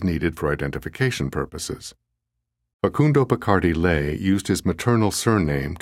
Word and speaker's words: Bacardi: I have used Spanish spellings for all names in this book, with the notable exception of Bacardi, which in Spanish is accented on the final syllable and pronounced Bacardi.